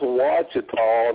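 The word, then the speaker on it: Ouachita: Yeah, well, so it's pronounced Wachita, and it's spelled Ochita or something, you know.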